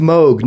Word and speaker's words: Moog: And it's pronounced Moog, not Moog. He pronounces it in there. He says it rhymes with Vogue. It doesn't sound like a cow going moo plus G. It's Moog.